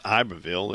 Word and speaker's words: Iberville: District Court of Louisiana in Baton Rouge and Iberville, for those of you outside of New Orleans where it's pronounced Iberville,